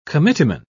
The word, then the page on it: committeeman: British English